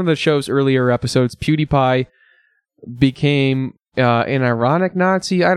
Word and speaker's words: PewDiePie: His name, I believe, the way you pronounce his name is PewDiePie.